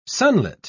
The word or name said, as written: sunlit